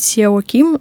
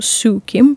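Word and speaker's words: Seo Kim: неправильно произносить Сео Ким, но пишется так, Сео Ким, по-моему, Сю Ким произносится.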